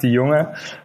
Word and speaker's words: de Jonge: Yeah, close, close. If I would pronounce it, it would be Frank de Jonge.